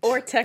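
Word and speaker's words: Ortecho: But she is returning to this tourist trap of her hometown, Roswell, and New Mexico as Liz Orcheta. Hashtag white. – Orteco. – That is me. Yeah. – I actually wrote it out phonetically so I could pronounce it Orteco.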